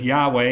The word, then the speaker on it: Yahweh: But we would pronounce it as Yahweh.